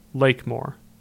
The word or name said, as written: Lake Moor